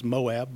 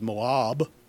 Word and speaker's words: Moab: I've always said it was Moab, but actually the pronunciation is Moab.